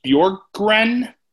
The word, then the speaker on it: Bjorkgren: Nate Bjorkgren. B-J-O-R-K-G-R-E-N.